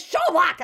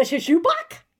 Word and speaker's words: Schoolbak: He's like, it's Schoolbak. And I'm like, the shishubak? The shishubu? Right?